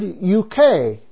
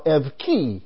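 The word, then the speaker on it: evki (εὐχή): But it's also a testimony to us, because the word vow is the Greek word euke, or as it is pronounced now, evki.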